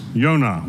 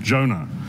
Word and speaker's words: Yonah: I'm sure many of you know the Hebrew word for dove is Yonah, which we pronounce Jonah.